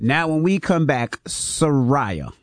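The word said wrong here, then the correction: Sy'Rai: Did I say that right? – Sarai. – Let me make sure. – Like, hey, Sarai, Sarai. It's just Sarai.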